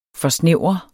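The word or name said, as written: FORSNÆVRER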